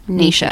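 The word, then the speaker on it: Naoise: Yeah, I've watched a lot of videos of how Saoirse Ronan pronounces her name, and it's spelt like Saoirse Ronan's name but just with an N. So it, and then I watched a video and I think it's Nisha Dolan. – Nisha Dolan.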